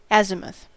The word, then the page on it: azimuth: American English; /ˈæzɪməθ/